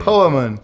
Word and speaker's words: Poemen: How do you say that name? – Poemen. – Poemen? – Yeah,